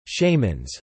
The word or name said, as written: shamans